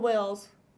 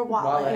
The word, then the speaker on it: Wale: Yeah, but then RM also collabed with Wales for the song Chain. – Wales? Or Wale? Wale, is that how you say it? I don't know. – Is it W-A-L-E? – Yes. – Yeah, Wale.